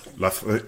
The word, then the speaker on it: Loughborough: Did I say that right? Loughborough? – Loughborough. – Jesus, I'm not even close.